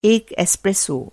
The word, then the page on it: एस्प्रेसो: Hindi